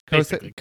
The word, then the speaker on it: Kosei: Kusei, I think his name is. Um, am I saying his name right? Yeah, Kosei. Kosei.